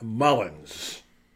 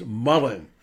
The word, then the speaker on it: Mullen: I don't want to be critical of you, but it's not Mullins. Okay? Just like it wasn't Myers show respect for your coach. Get his name right. It's Mullen, M-U-L-L-E-N, Mullen.